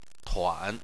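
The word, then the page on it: tuan: tone 3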